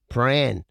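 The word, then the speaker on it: Prahran: It's like Pran. – Yeah. – You know, you go and have a look at the shops up in Pran.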